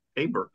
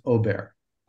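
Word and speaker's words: Åberg: Yeah, it's Aber. No, it's Aber. – No, it's Ober.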